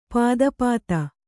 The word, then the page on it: ಪಾದಪಾತ: pāda pāta